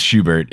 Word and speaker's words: Schubert: It is Schubert.